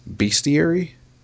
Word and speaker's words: bestiary: Forgive me if I butcher the name of this. Bestiary? Is that how you pronounce that? – Yes.